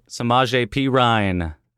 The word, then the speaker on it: Samaje Perine: Samaje Perine. It's weird that's how it's pronounced.